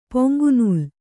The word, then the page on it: ಪೊಂಗುನೂಲ್: poŋgu nūl